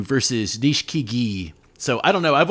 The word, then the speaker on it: Nishikigi: You know how the Japanese kind of truncate some of those vowels. You say Nishikigi, and then you hear other guys pronounce every vowel. So it's Nishikigi.